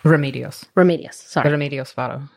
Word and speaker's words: Remedios Varo: And I do know also, I don't know how to pronounce her name, but Remedios Varo. She did a lot of self-portrait photography of herself. – Remedios Varo.